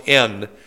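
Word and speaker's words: en (ἐν): Because in the Greek language, the word in, which is almost the same word, it's pronounced en, but it draws a circle or a sphere around an object.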